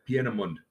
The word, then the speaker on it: Peenemünde: Peenemünde was the... – Peenemünde.